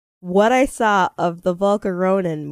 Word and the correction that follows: Volcanion: movie. – Volcanion. – Volcanion movie.